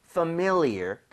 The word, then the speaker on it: familiar: 그래서 familiar보다는 familiar. 그래서 familiar. MI 쪽에 강세를 주고 한번 발음해 볼게요.